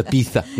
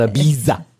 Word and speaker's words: Ibiza: It's Ibiza, not Ibiza. – It is. – It's spelled Ibiza, but it's Ibiza. – I can't help but laugh, though, every time I say Ibiza. – I know. It's kind of weird, isn't it? It's like, there's no TH in that. TH is silent and replaced with a Z.